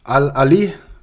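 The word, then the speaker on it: Al Ahly: Øhm, semifinalen mod Al, Al Ali udtales det.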